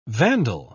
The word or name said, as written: vandal